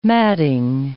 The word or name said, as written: madding